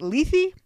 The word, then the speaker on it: Leith's Bramble: Joss Whedon pronounces it Leithy. I hate that. It's Leith's Bramble for me.